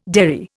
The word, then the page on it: derry: Indian